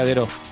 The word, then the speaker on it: Dairaux: que en realidad se dice Dero. ¿eh? Dairaux, la localidad de Dairaux se pronuncia Dero,